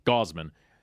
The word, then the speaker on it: Gausman: I mispronounce his name every time. Gausman.